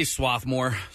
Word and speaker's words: Swarthmore: And Campos Deli and is it Swarthmore Co-op? I got to make sure. – I say Swarthmore.